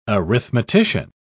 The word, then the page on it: arithmetician: British English